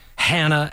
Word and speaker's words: Hanna: And in my opinion, one of the best is going to that beautiful town that rhymes with banana, Hanna, Alberta.